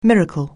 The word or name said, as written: miracle